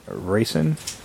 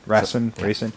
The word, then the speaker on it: Rasen: The Japanese name was Raisin. Rasen.